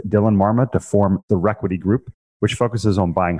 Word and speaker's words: Requity: it's Requity, and I see R-E, and I always say Re. So, yeah, so the Requity Group, R-E-Q-U-I-T-Y.